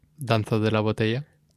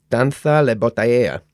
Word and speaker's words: Danza de la botella: How do you say it again? – Danza de la botella. – Danza la botella.